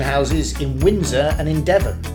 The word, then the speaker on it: Devon: From Devon, rhymes with heaven.